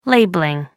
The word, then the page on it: labelling: American English